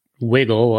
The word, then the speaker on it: WiGL: That's pronounced Wiggle, like you wiggle in your chair, but it's spelled W-I-G-L, and we'll talk about what that is.